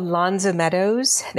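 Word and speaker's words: L'Anse aux Meadows: I think it's called Lance O'Meadows. They sort of slide it together. It's probably not very French, but I think that's the way the locals pronounce it.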